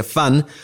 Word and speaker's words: VAN: La redacción aclara que se pronuncia como la palabra inglesa Fan o como en Ludwig van Beethoven.